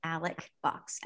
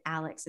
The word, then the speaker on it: Alex Box: But, like, I know this goes for a lot of SEC stadiums, but Alec Box Stadium, first of all, it's Alec. It's spelled Alex. I feel like I have to let people know this. It's spelled Alex Box. It's Alec Box. Anybody who pronounces it Alex is incorrect.